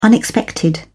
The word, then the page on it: unexpected: British English